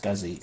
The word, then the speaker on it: SCSI: SCSI, Small Computer Systems Interface, SCSI, which is spelled SCSI, by the way, if you didn't know, but it's pronounced SCSI, is a type of interface that uses parallel communication technology to achieve high data transfer rates.